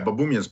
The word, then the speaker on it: Baboumian: Yeah, Baboumian's perfect.